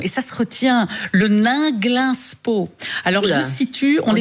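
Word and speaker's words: Ninglinspo: C'est écrit comme ça se prononce. – N-I-N. Absolument. – G-L-I-N. – Oui. Nainglinspo, avec O au bout.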